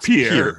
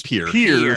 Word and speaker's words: Pierre: Okay, but it's not Pierre. It's Pierre, South Dakota.